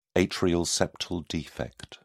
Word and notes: atrial septal defect: British English

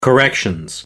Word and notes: corrections: British English

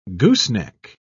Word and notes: gooseneck: British English

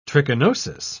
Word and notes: trichinosis: British English

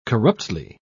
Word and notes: corruptly: British English

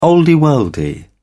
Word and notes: olde worlde: British English

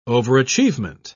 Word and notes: overachievement: British English

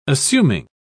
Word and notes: assuming: British English

